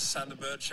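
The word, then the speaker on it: Sander Berge: Men så får vi egentlig bare bekreftet at det bare sånn han uttaler navnet sitt. – Ja, skal vi se. – Hi Burnley fans, Sander Burge here.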